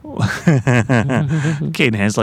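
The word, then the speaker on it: Cadenhead: Caden Heads, like most how Scots pronounce it.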